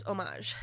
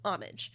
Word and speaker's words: homage: The French pronunciation is homage. – Yeah. – The American pronunciation is homage.